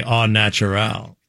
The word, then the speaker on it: au naturel: As I like to say, au naturel. – That's the correct way to say it.